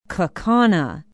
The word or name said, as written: Kaukauna